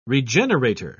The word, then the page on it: regenerator: British English